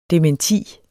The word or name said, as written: dementi